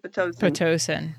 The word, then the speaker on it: Pitocin: After that Pitocin. Is that how you pronounce it? Pitocin. – Yeah.